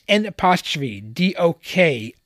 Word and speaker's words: N'Dokolidji: During the Sovereign Nations Conference in February 1990, she sang the hymn for peace, Ndokligi. It's N'Dokolidji. You pronounce it.